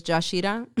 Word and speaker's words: Yashira: So it's Yashira. Y-A-S-H-I-R-A.